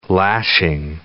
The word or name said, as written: lashing